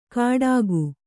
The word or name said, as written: ಖಡಕು